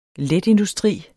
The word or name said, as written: letindustri